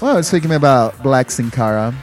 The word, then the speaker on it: Sin Cara: How do you say this? – Sin Cara. – There you go.